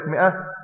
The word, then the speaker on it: مئة: غلط، الواو دي مكتوبه بس تكتب ولا تنطق، والالف اللي موجوده في كلمه مئة تكتب ولا تنطق.